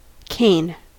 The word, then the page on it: cane: American English